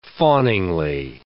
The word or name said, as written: fawningly